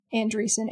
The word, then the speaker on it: Andresen: If you want, it's Erika, E-R-I-K-A, dash, Andresen, A-N-D-R-E-S-E-N.